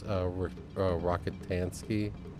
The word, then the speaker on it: Rockatansky: With Tom Hardy playing Max Rockatansky, is that how you say it? – Yeah.